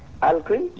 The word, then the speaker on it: Alcuin: Who listened to Alcuin. – Alcuin? – Yes. – A-L... How do you spell that? – A-L-C-U-I-N, okay.